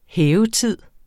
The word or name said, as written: hævetid